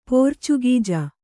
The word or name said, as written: ಪೋರ್ಚುಗೀಜ